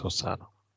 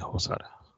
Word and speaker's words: Hosoda: I say I'm probably gonna butcher this, but is it Osada or Hosada? – I think it's Osada. – Okay, I'm asking you shit. – No, it would be Hosada.